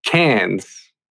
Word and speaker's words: Cairns: I've been to Sydney and Cairns, which is spelled Carnes. But I was asking people, I was like, have you heard of Carnes? And they were like, what is your problem? – Yeah.